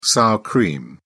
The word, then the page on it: sour cream: British English